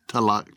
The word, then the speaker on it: Tagalog: Tagalog.